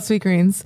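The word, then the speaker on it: Sweetgreen: Sweet greens? – Sweet greens. – I'm like, it's sweet green. He's like, no, sweet. Do you want sweet greens? – Like, no. So many people call it sweet greens.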